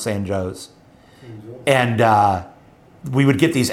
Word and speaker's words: San Jose: It's like, you need to just know that if it's in Illinois, it's pronounced San Joe's. So, okay, San Joe's, Illinois.